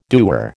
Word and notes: dewar: American English

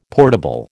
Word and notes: portable: American English

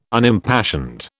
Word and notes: Unimpassioned: British English

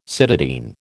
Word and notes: cytidine: American English

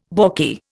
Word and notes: balky: Indian

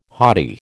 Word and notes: hottie: American English

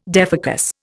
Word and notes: defocus: Indian

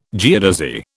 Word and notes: geodesy: British English

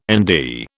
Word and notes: Nd: British English

